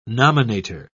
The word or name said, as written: nominator